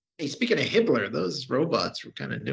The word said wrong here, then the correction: Himmler: But, it's pronounced Himmler.